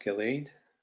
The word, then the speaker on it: Gilead: Therefore was the name of it called, I believe that's pronounced Gilead.